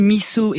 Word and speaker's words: miso: Alors je dis miso et pas miso, car c'est comme ça qu'on le prononce au Japon.